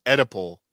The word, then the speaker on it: Oedipal: Yes, it's pronounced Oedipal, a Greek reference to Oedipus.